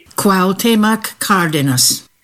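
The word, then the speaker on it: Cuauhtémoc Cárdenas: Cuauhtemoc Cárdenas.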